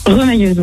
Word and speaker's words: remailleuse: C'est ce que c'est, apparemment, rémailleuse. – Remailleuse. – Ça se prononce pas comme ça? Remailleuse? – Remailleuse, oui. – Remailleuse, pardon.